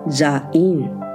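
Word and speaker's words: Zain (זין): 西湾月是希伯来文 Zain 的月份， Zain 是希伯来第七个字母，数值是七，读作 Zain。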